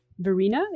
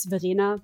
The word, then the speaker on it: Verena: So my name's Verena, if you want to pronounce it that way. That's the American pronunciation. In German, it's Verena, but yeah. We also roll our R's, so it's okay.